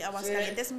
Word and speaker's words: Aguascalientes: From Aguascalientes, Mexico. – Talk that shit. – Ooh, ooh, let me say it correctly. Aguascalientes, Mexico.